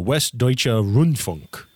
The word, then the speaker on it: Westdeutscher Rundfunk: It stands for the, I'm going to butcher this pronunciation, the Westdeutscher Rundfunk. – That's pretty good.